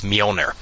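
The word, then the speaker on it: Mjölnir: The J is silent. Well, actually, not exactly silent. It does like a Y sound, so it's Mjolnir.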